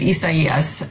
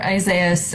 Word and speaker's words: Isaias: I'm going to start with the dumbest question of all because I've heard four different pronouncers on this storm. I've heard Isaias. I've heard Isaias. I've heard Isaias. What is it? – I mean, the actual correct pronunciation is Isaias. For those of us in the U.S., you'll hear Isaias,